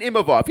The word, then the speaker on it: Imavov: How do you say this? Imavov? – Nasruddin Imavov. Yeah, you got it.